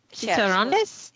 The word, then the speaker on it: chicharrones: Chicharrones? – Yes, thank you.